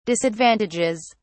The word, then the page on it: disadvantages: British English